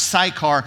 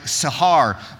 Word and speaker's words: Sychar: In English, that's pronounced Sychar. In Hebrew, it's like Sahar, but in English, we say Sychar.